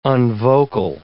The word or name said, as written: unvocal